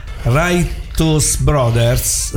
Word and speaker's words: Righteous Brothers: Quindi questo pezzo tornò in testa alle classifiche del 1965 con in Righteous Brothers. Ho detto bene, Francesco? Ho detto bene, meno male.